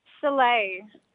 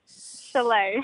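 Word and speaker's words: Shule: Shalay. – What? – Shalay.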